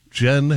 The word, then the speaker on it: Jyn: J-Y-N, pronounced gin, as in the drink.